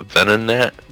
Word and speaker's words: Venonat: It's gonna use Ember on the Venonat. I can't pronounce that. – Okay. – Is that... How do you say that? Venonat? – Venonat.